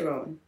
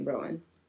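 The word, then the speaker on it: Roughan: Her parents' names were Barbara Rohan, I believe it's pronounced. It's R-O-U-G-H-A-N. Rowan? Maybe just Rowan. Maybe I don't pronounce the H. – Yeah. – I'm going to say Rowan. I'm just going to go with that.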